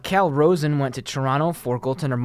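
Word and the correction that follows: Calle Rosén: It's Callie Rosen. – Callie Rosen? Okay. Wow. Well, Cal is the Canadian pronunciation because now he is in Toronto. Definitely correct on that.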